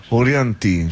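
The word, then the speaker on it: Orianthi: Io non so se si pronuncia così, Orianti. – Orianti, sì, credo anche io.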